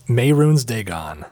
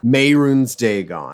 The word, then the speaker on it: Mehrunes Dagon: Mehrunes Dagon. – Mehrunes Dagon.